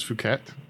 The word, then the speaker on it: Phuket: It is Phuket.